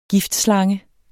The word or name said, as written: giftslange